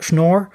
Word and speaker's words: Schnorr: And so all the nice properties of discrete log, which enabled signature schemes like Shor, don't work for... I mispronounced the name. So all the nice properties of discrete logarithms that make for good signature schemes like Schnorr don't transfer to isogenies.